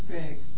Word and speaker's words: Begg: How do you spell that last name? – B-E-G-G. – Huh? – B-E-G-G. – B-E-G-G. – Begg. – Big J. – Begg. – Begg, that's his name.